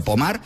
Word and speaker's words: Pomar: Se escribe Pomar, como si fuera en castellano, pero Pomar, pues allí en Francia.